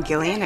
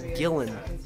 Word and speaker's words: Gillan: Ditching class to go see Karen Gillan at Comic-Con? – Hello, it's pronounced Gillan.